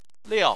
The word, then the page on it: liao: 4